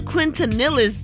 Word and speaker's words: Quintanilla: Y'all, Selena Quintanilla's, did I say it right? Mm-hmm. Quintanilla's killer has spoken out from behind bars 30 years after the singer's death as she prepares for parole eligibility in 2025.